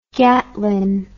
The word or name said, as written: gatling